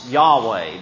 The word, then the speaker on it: Yahweh: And that's described in the Hebrew by four letters, which our English letters are not Hebrew, obviously, but the four letters would transliterated be YHWH, which we pronounce Yahweh.